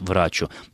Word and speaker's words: врачу: поэтому никаких оснований для того, чтобы произносить это слово с иным ударением, кроме, как подчеркну еще раз, врачу, не существует.